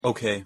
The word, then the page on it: ok: English